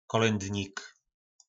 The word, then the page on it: kolyndnik: po našimu